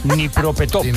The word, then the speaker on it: Dnipropetrovsk: Dnipropetrovsk. ¿Cómo se dice esto? ¿Quién ha sido? ¿Quién ha metido esta? – Dnipropetrovsk.